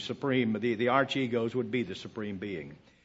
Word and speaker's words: Archegos: A-R-C-H-E-G-O-S.